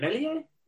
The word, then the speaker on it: Meslier: Meslier? – Yeah.